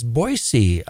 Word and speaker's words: Boise: And while I'm on that kick, it is Boise, Idaho, not Boise, Boise. So remember that next time and do a good Idahoan a favor by pronouncing those names right, Moscow and Boise.